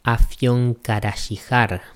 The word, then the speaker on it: Afyonkarahisar: Al parecer, ese nombre está sacado de una pequeña ciudad turca que en el idioma original se pronuncia algo así como Afyonkarahisar.